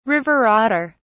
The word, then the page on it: river otter: British English